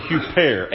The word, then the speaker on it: huper: And little word for that we pronounce and say in the Greek is the little word huper, H-U-P-E-R.